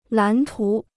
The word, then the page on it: 蓝图: lán tú